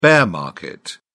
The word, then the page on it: bear market: British English